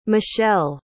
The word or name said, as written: mechelle